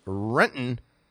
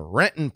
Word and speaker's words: Renton: Brian writes, catching up on yesterday's episode, I have lived in Renton for 6 years and it took me four to pronounce it right. You did it perfectly. That T almost disappears and the O is non-existent.